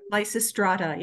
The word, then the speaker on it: Lysistrata: And you use two examples, one with a name that I can't even pronounce, Lysistrata. Is that good? – Lysistrata. Yeah. – There you go.